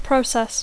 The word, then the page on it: process: English